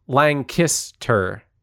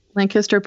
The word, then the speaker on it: Lancaster: Didn't Lancaster put out shirts with the phonetic pronunciation on it? – Yes, it was like Lang and then Kiss, K-I-S-S, and then Tur.